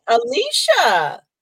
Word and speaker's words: Alyssa: So it's not like it looks or how it's spelled. I say Alicia like it has an SH in it. – Okay. I like that. I like that. Alicia.